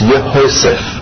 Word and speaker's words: Yehosef: It's not Yosef, it's Yehosef.